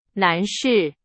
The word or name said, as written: 男士